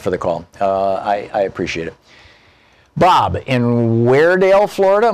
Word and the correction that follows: Weirsdale: Am I saying that right, Bob? – No, it's Weirsdale. – Weirsdale. Okay, what's up? – Need to put an S in there somewhere.